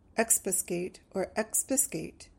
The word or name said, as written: expiscate